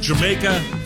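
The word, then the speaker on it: D'yer Mak'er: The title of this one is pronounced Jamaica. Looks nothing like it.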